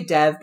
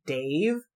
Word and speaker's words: Dev: So we have, Anya, Dave, I'm going to say Dev because it's spelled D-E-V, but I know it's pronounced closer to Dave.